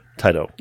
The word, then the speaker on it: Taito: It's because in English we had that vowel shift that I don't think anybody else had, but it's Taito.